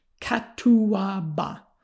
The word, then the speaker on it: catuaba: And it's pronounced properly with a bit of South American flourish, catuaba. Although I'm gonna probably just say it in a in in an American kind of way.